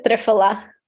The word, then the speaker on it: triphala: But let's talk about a few other ones that benefit the gut, namely ginger and triphala. Am I pronouncing that correctly? Or we pronounce it triphala.